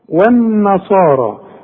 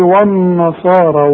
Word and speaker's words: والنصارى: والنصارى يا ريتك تنطقها زي ما انا نطقتها انما انت هتقول لي والنصارى والنا شوف و والنا غلط ده انت لسه قايل دلوقتي والذين الواو دائما مرققه هنا ليه فخمتها لي قلت لي وا